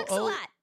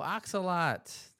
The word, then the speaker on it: Axolo: It is Ken A-X-O-L-O. – Axolot. – Oh, Axolot.